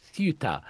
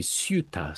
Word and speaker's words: Ceuta: It's spelt, if you want to look for it online, Ceuta. The people on the Spanish mainland, they probably pronounce it something like Ceuta. Oh. But my Spanish pronunciation isn't that good. And in England, we say Ceuta. So that's what I'll say.